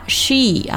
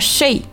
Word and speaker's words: she: she 啊，不要读成 she，注意字母 e 发的是一个 e 的音啊，发这个音的时候嘴角一定要往两边咧一点，比较紧张的去发一个 e 的音， she 啊，嘴部的肌肉是比较紧张的， she 不要读成 she。